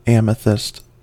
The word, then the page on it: amethyst: American English